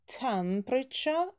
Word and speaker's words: temperature: Произносим temperature, ударение на первый слог.